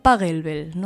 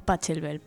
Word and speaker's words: Pachelbel: Bien, comenzamos por un golpe bajo que nos ha alcanzado a todos, incluso a mí, y es que se pronuncia Pachelbel, no Pachelbel, porque es francés.